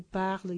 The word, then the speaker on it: parles: Es decir, la primera, segunda, tercera persona singular y la tercera, el plural, se pronuncian en el caso de esos verbos regulares igual. Je parle, tu parles, il parle.